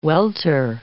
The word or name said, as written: welter